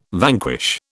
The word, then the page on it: vanquish: British English